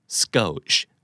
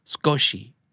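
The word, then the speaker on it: skosh: S K O S H อ่านว่า scotch ไม่ scotch นะครับผมก็เคยอ่าน scotch มาก่อนแต่พอไปเสิร์ชดูอ๋อมันออกเสียงว่า scotch เพราะว่ามันมาจากภาษาญี่ปุ่น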